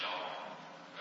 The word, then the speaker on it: Yahweh: The name of God is YHWH. So it's just been now to pronounce it as Yahweh.